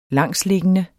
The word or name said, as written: langsliggende